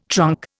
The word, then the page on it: trunk: Indian